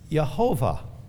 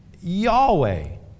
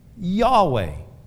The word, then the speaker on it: Yahweh: Now, like I said at the beginning of this study, the Lord in this verse is the Hebrew word Yehovah. But saints, a more accurate way of pronouncing this name of God in the Hebrew language is Yahweh. Yahweh.